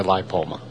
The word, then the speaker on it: lipoma: The more common, specifically to older horses, would be a lipoma.